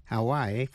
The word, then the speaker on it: Hawaii: Tiểu bang Hawaii, Hawaii phát âm là 3 syllables, Hawaii. Tiểu bang Ohio, nhấn mạnh vào vần nhì, nhưng Idaho, I-D-A-H-O, nhấn mạnh vào vần đầu, Ohio, Ohio, Idaho.